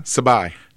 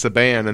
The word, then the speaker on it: Sabai: I'm sure it's pronounced Saban. And then when I got there, I went, oh, Sabai. Okay, A-I, not A-N, but it's a real curly I, and it makes it look like an N.